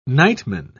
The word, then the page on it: nightman: British English